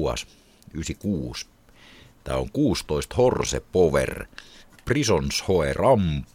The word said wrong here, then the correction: Romp: Romp, ei Ramp, ja Sack Lot and Ashes on levyn nimi.